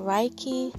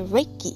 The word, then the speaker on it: Reiki: Well, it's pronounced Reiki, but in Western civilization, from what I found out, it's been turned into Reiki.